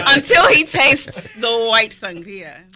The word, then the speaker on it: sangria: Okay, you've heard me all day roll my R when I say white sangria. – We love it. – Okay, roll your R. Nice. Give it the respect that it's due.